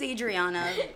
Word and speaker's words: Adriana: My name's Adriana, in case anyone was wondering, with one N, and my name's not Lola, but it rhymes with granola, so it does.